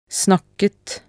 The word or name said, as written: snakket